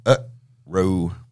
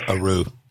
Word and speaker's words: Aroo: And it's spelled A-R-O-O. But it says on his page it's pronounced Uproo. – I always pronounce it Uproo.